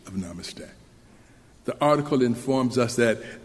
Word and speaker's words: namaste: So the word is correctly pronounced, namaste, namaste.